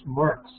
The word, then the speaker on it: Marks: His name was Bruce Marx, M-A-R-K-S, pronounced like Karl Marx.